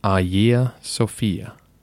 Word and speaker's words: Hagia Sophia: Of course, in English, the name looks like it should be said Hagia Sophia. But we lack the soft G sound, which you would find in Greek, and we use the letter H, which they do not. In modern Greek, the pronunciation should be Hagia Sophia.